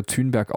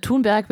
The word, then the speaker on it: Thunberg: Ich glaube, ähm, Thunberg wird es geschrieben und Thunberg ausgesprochen.